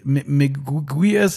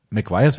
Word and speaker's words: McGuire's: So, und dann, das Gute ist, die meisten Leute wissen, dass sie es falsch aussprechen und legen schon vor, sagen, ich weiß jetzt nicht genau, wie es Miguayas ist. Und dann weiß schon, okay, der hat mir schon den Ball zugespielt, dass ich sage, McGuire's wäre korrekt, aber ich weiß, was sie meinen, so, McGuire's, okay.